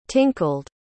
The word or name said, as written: tinkled